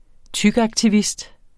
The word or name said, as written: tykaktivist